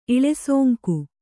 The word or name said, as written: ಇಳೆಸೋಂಕು